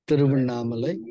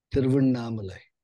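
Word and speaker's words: Tiruvannamalai: Thiruvannamalai. It's spelled as T-I-R-U-V as in Victor, A-N-N-A-M-A-L-A-I. – Okay, Thiruvannamalai. – Thiruvannamalai.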